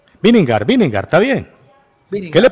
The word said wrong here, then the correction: Vingegaard: Bingegar, así se pronuncia, Bingegar.